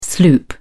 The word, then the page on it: sloop: British English